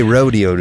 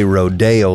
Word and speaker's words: rodeo: We don't say rodeo like in Hollywood with Rodeo Drive. They don't know how to say the word right. We say rodeo the way you're supposed to say it.